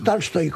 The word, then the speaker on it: Tolstoy: No es exactamente Tolstoy, es Tolstoy. – ¿Cómo? Lo dije. – Es Tolstoy, con A, una. – Embajador. Lo dije yo así, tal cual. Tolstoy. – Correcto, es una Tolstoy. Es una A que tira hacia el O, pero que es A.